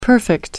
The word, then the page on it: perfect: American English